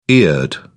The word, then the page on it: eared: British English